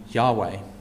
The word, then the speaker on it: Yahweh: So the word the Lord in English is actually a proper noun, and it is the name Yahweh, which is how you pronounce it in Hebrew.